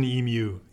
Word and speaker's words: emu: Now, how do we say it? Is it emu eggs? Am I saying this correctly? – You can emu. I did receive the email interaction from our friend in Australia. It is an emu egg.